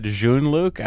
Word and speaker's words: Jean-Luc: I think if you had said Jean-Luc, I would have gotten it. – Oh, I pronounced it wrong. It wasn't French enough for you?